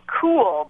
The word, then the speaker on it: Cuil: And it was called Cool, but it was spelled C-U-I-L, and nobody knew how to pronounce it.